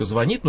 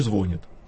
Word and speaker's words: звонит: Норма диспозитивная позволяет нам говорить «творог» и «творог», а норма императивная говорит, что можно ставить ударение «только звонит», но не звонит.